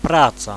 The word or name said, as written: praca